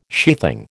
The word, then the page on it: sheathing: American English